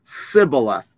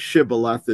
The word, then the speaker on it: Shibboleth: Now, the Ephraimites were raised to pronounce the word with a dot here, which made it the letter sin, which would be pronounced Sibboleth. Sibboleth is the Hebrew word. Shibboleth is the Hebrew word for river, or waterway, or flood.